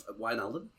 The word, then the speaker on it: Wijnaldum: So we're linked with Wijnaldum. I can never say his name. The ex-Liverpool midfielder. – Wijnaldum? – Wijnaldum. Okay.